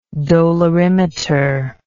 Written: dolorimeter

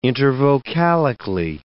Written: intervocalically

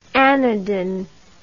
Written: anadin

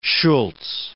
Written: Shultz

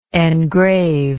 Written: engraver

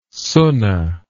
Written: sunna

